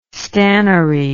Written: stannary

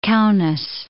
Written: Kaunas

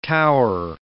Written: tower

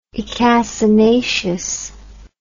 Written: icacinaceous

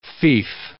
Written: fief

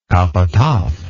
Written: capataz

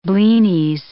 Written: blinis

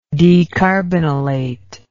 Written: decarbonylate

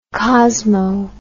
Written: cosmo

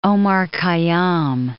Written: Omar Khayyam

